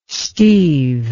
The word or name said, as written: steeve